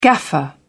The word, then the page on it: gaffer: British English